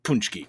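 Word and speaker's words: pączki: Brandon, this is pronounced Puczki, correct? – Yes.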